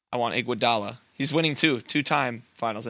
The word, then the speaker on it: Iguodala: Iguodala. – Andre Iguodala.